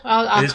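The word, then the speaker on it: Berge: I think I keep calling him Berger, but it's Berg, right? Sander Berg. – I think it's Berg. Sander Berger. – Berger. Sorry. – That long, I know. This is it. I've forgotten how to say his damn name. Yeah, Berger. There we go.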